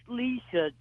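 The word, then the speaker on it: Licia: Is it Lycia? – No, it's Lisa, George. – Spell it for us, would you? – L, I, C as in Charlie, I A.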